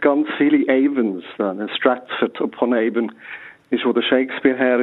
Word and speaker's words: Avon: Sagt man eigentlich on-Avon? Guten Morgen. – Guten Morgen, Frau Bernasconi. Avon heisst es.